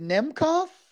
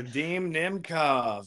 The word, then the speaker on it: Nemkov: Is it Nemkov? – Yes. – I want to make sure I'm saying that right. – Vadim Nemkov.